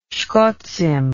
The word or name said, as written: shkotzim